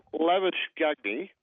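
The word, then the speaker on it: Labuschagne: Don't forget, you got Lavish Gagne,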